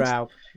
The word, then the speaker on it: Straub: And it was his film Ghost Story, made in 1981, an adaptation of the book by Peter Straub, or Straub, I think it's Straub. – It's pronounced. Straub, yeah.